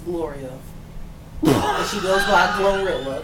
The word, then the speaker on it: GloRilla: There's pros and cons, because if my thing was personal assistant and I'm riding with Gorilla, is that how you say her name? – Glorilla. – Glow? – Yeah. G to the L to the OG Glow.